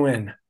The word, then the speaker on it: Nguyen: And I said, oh, it's pronounced Win. Sorry, Win.